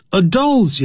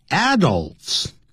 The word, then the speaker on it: adults: Adults 也可以念成 adults， dull 因为有个 L 的音，所以呢嘴巴最后的时候欧洲的 O 那个音，玩偶的偶那个音要念成 adults， adults， adults，念 adults 也可以。